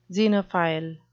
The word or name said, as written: xenophile